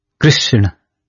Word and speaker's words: Krishna: He said it is Krishna. It is Chotari. – Mm-hmm. – And he said, if you pronounce wrongly, the meaning changes.